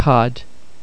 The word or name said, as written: cad